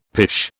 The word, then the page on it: pish: British English